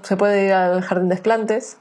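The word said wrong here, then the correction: Jardin des Plantes: Sí, no se dice así. – Es Jardín de las Plantas o de Plantes. – Sí, Jardín de Plantes.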